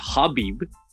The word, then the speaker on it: Khabib: I always feel bad when I say Khabib because DC always says Habib, which is, I think, the K is silent.